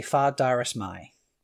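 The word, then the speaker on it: Far Dareis Mai: The most accepted way of saying this, obviously you can say it however you want to, most people say Far Darius Mai. So you were extremely close with the most commonly accepted pronunciation of that.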